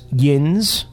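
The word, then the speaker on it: yinz: On a side note, the Northern Ohio Western Pennsylvania word that means y'all is pronounced yins.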